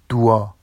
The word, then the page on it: dua: Vafsi